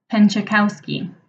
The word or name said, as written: Penczykowski